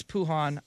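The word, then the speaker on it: Pouhon: He's going through, I believe it's Pujan. Pujan? – Yep. – Yeah. I believe it was Pujan. I apologize if I butcher the pronunciation of that.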